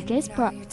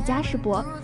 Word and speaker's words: Jasper: Jasper 还没开口说话，而纠正读音是 Jasper，不是嘉士伯，真是个贴心的小暖男。